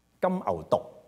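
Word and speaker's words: 金牛犢: 但是剛才先說到有這件事發生了嘛，金牛毒啊，大家不用疑惑那個字，那個字讀做毒，金牛毒，OK，個發音好像毒字啊、中毒啊那個毒字咁，OK，下次大家讀聖經讀到個字唔使猶豫，金牛毒那個字即係小牛咁解啊。咁我哋咧有隻金牛毒事件出現，咁你見到呢隻嘢，哇，係你仲 call 錢 call 人開工，好勞民傷財噶咯喎，呢件事已經，你諗下用咗佢幾多金啊，係唔係啊？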